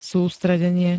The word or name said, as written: sústredenie